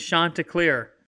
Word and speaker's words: Chanticleer: The proper pronunciation is Chanticleer.